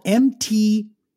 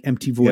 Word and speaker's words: MTVoid: So this week on the show, we have got, a band called MTVoid, and it's spelled MTVoid, like MTVoid. Yeah. So like MTV, MTVoid. Yeah. MTV is void.